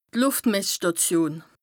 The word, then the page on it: d' Lùftmessstàtion: Bas Rhin